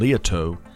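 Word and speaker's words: Liautaud: The facility was built and was named Toad Hall, a name chosen by Jimmy John Lieto because of how frequently folks mispronounce his last name, which, by the way, has a silent D at the end.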